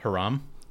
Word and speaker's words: Hiram: Is this fourth panel down? H-I-R-A-M. – Hiram? – It's Hiram.